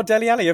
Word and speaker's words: Dele Alli: Rhymes with Melly Nally. – Oh, Dele Alli, of course.